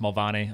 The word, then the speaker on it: Mulvaney: So Scott William Mulvaney over there, it's pronounced Mulvaney, obviously Irish by bloodline.